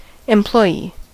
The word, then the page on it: employee: /ɛmplɔɪˈiː/; /ˌɛmˈplɔɪiː/; American English